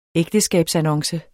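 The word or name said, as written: ægteskabsannonce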